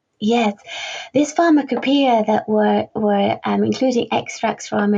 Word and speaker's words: Pharmacopoeia: So also in your book, you've got extracts of historical publications, including the Edinburgh, and I apologise if I say this wrong, Pharmacopoeia? – Oh, very well pronounced. Absolutely perfect.